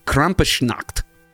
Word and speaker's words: Krampusnacht: which was called Krampusnacht. Is that right, Riley? Did I say that right? – Yeah.